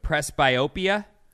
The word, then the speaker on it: presbyopia: It's an age-related condition that we're talking about, and I don't know that I'm going to say this right, but presbyopia? – Yeah, that sounds right.